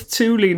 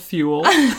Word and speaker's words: Thule: Someone commented on YouTube and was like, ha ha ha ha ha, it's Thule, not Thule.